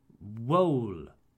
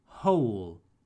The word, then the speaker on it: whole: So I hear wool and woo. Sorry, guys, the W here is silent. I know it's confusing because in where or what we hear the W, but not in whole pour entier or who pour qui.